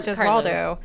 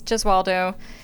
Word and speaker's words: Gesualdo: Yeah, Carlos Gesualdo. – The S is silent. – Is it? – Yeah. – Oh, that's funny. In Italian, though, you say the S, but maybe not back then.